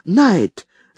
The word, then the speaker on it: Knight: «Найт», — сказал я. «Это английская фамилия. Она пишется не так, как произносится. K-N-I-G-H-T.